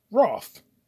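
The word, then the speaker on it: Rothe: And the girl in the movie, her name is Jessica Roth with a silent E.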